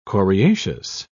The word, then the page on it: coriaceous: British English